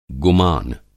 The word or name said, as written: गुमान